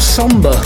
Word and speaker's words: Somber: This is called Somber. One of those words with a silent letter in it. In this case, R.